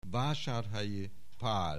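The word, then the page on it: Vasarhelyi Pal: Hungarian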